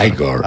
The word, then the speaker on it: Igor: Y bueno, toda la parafernalia eléctrica en torno a la creación del monstruo, el gran personaje secundario, Igor, pronúnciese Igor.